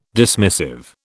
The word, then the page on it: dismissive: British English